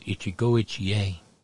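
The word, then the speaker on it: Ichigo-ichie (一期一会): It's pronounced Ichigo-ichie.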